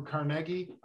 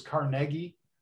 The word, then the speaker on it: Carnegie: And, you know, other than perhaps Carnegie, Andrew Carnegie, I always pronounce it that way, Zach, because that's what I heard, even though everybody says Carnegie Mellon. I always heard it was Carnegie. – It probably was Carnegie in the Scottish.